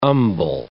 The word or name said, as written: umbel